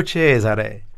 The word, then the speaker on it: Cesare: These words like Cesare break the rule. They're called sdrucciola in Italian. They accent on the first syllable rather than the penultimate.